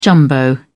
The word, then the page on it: jumbo: British English